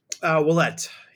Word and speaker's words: Ouellette: Oullette, yep.